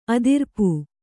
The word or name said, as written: ಅದಿರ್ಪು